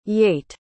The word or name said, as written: Yate